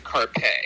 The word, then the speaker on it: Karpeh: We just pronounced it Carpe. Carpe. Carpe. – Carpe? – K-A-R-P-E-H.